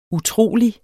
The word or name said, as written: utrolig